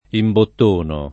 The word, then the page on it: imbottonare: italiana